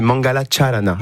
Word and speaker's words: Mangala Charana: Ça s'écrit pas comme, ça se prononce pas comme ça s'écrit. C'est Mangala Charana.